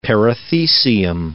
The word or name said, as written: perithecium